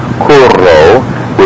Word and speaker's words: curro: Again, curo, curro. Notice I accented the U a little bit there, but the important thing to notice is that the double R is a bit longer.